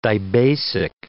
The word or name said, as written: dibasic